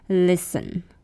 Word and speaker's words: listen: 最后一个呢，就是我常常会讲的，你应该也会常常讲，常常听到这个字叫做 listen. listen. L-I-S-T-E-N, 虽然它有 S-T-E-N, 可是它的 T 是 silent, 它的 T 是不发音的。所以你在念的时候只有 L-I-S-E-N 的音 listen, listen, listen, listen, listen to me, I'll listen to you if you listen to me.